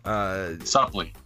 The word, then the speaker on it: Supplee: Supley.